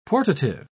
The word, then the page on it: portative: British English